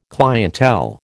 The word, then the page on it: clientele: American English